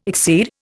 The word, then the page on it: exceed: Indian